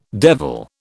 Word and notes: devil: British English

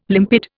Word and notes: limpid: Indian

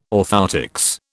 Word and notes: orthoptics: British English

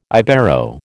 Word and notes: Ibero: American English

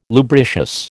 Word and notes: lubricious: American English

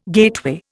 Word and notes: gateway: Indian

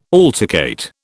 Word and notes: altercate: British English

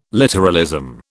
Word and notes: literalism: British English

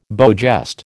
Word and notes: beau geste: American English

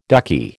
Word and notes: ducky: American English

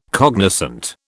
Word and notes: cognoscente: British English

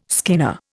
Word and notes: skinner: Indian

